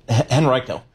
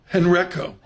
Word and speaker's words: Henrico: Henrico. – Henrico.